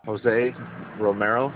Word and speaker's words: Jose Romero: Jose Romero. – And how do you spell that? – J-O-S-E. Last name is R-O-M-E-R-O.